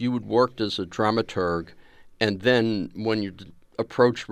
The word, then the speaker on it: dramaturg: Is it dramaturg or dramaturge? – We dramaturgs like to do the hard G at the end.